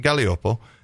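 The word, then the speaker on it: Gaglioppo: And it looks like Gagliopopo, but it's pronounced Galliopo, and it's mainstay of Calabria, the Toe, and then getting up into Basilicata.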